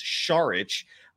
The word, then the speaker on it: Šarić: You actually do enunciate the start of his last name with an SH sound, so it's Saric.